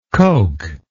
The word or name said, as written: coak